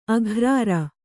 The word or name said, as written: ಅಗ್ರಾರ